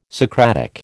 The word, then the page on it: Socratic: American English